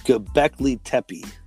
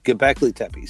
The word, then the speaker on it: Göbekli Tepe: It's called Göbekli Tepe. Is the correct pronunciation. Göbekli Tepe.